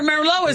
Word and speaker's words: Merlot: Yeah, remember Merlot is, and that T is silent on the end of Merlot.